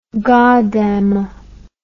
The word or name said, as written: gadamer